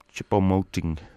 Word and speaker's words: Choupo-Moting: Choupo-Moting.